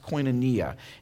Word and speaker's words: koinonia: And it's the Greek word that's pronounced koinonia.